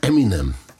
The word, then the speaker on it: Eminem: Es gibt einmal Eminem. Das war ja nichts anderes ist als die Aussprache seiner Namensinitialen M&M. Ja. Bisschen verschlurrt. Eminem.